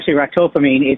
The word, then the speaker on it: ractopamine: It's banned in China, called ractocamine. What is this stuff, ractocamine? Maybe you can tell us that first. – It's pronounced ractopamine.